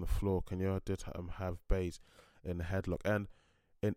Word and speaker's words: Buys: It was pronounced by one of the commentators, it was pronounced Bays, but her name is spelled B-U-Y-S, so I'm assuming that U is spelled like pronounced almost like an A.